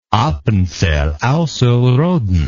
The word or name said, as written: appenzell ausserrhoden